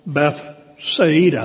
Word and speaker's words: Bethsaida: "And taking them with him, he withdrew privately into a city called Bethsaida," actually is the way it's pronounced.